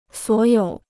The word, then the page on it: 所有: suǒ yǒu